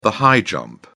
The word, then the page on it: high jump: British English